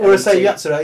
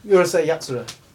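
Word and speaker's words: Urusei Yatsura: Y-A-T. – Uruseei Yatsura. Yeah, vinyl. – How do you say it again? – Uruseei Yatsura.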